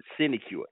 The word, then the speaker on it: sinecure: That's the correct pronunciation of this word, sinecure. S-I-N-E-C-U-R-E.